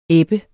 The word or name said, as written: ebbe